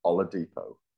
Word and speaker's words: Oladipo: Mr. Oladipo.